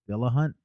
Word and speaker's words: Dillahunt: Dillahunt, is that how you say his name? – Yeah.